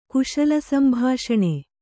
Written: ಕುಶಲಸಂಭಾಷಣೆ

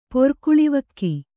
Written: ಪೊರ್ಕುಳಿವಕ್ಕಿ

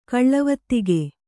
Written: ಗಳವೊತ್ತಿಗೆ

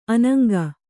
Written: ಅನಂಗಿ

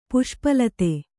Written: ಪುಷ್ಪಲತೆ

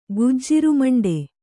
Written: ಗುಜ್ಜಿರುಮಂಡೆ

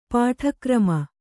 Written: ಪಾಠಕ್ರಮ

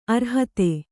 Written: ಅರ್ಹತೆ

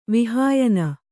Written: ವಿಹಾಯನ

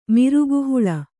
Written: ಮಿರುಗುಹುಳ

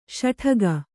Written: ಷಡಕಿ

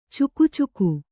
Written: ಚಕಚಕ

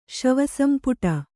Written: ಶವಸಂಪುಟ

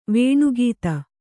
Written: ವೇಣುಗೀತ

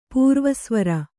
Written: ಪೂರ್ವಸ್ವರ

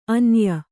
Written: ಆನಯ

